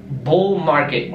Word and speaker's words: bull market: Tá, essa palavra é praticamente a mesma coisa, mas você vai falar bull market, e o T também, de novo, quase não é pronunciado, bull market.